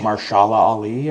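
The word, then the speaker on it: Mahershala Ali: And Marshala Ali, I believe is how you say his name.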